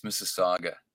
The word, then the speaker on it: Mississauga: Mississauga? How do you pronounce the name of your? – Oh, that's Mississauga.